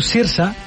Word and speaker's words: Saoirse: que es diu Saoirse, m'agrada pronunciar-ho bé, ja que ho vaig aprendre, sempre hi ha de Saoirse o...